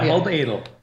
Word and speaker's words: Halbedl: Halbedl. – Halbedl. What is wrong with me? – The DL at the end that is scaring people too.